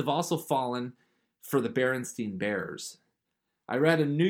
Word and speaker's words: Berenstain: Berenstain, okay. Turns out Berenstain is spelled S-T-A-I-N.